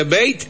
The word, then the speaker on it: bait: Bien, bueno, hemos aprendido entonces eh, F, F, así nomás, buguearse, buguearse, y eh, se escribe byte, pero se pronuncia bait.